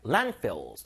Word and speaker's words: landfills: Landfills. 들어보세요. Landfills. 앞에 강세 주세요.